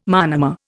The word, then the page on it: monomer: Indian